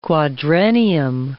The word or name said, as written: quadrennium